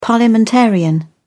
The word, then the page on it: parliamentarian: British English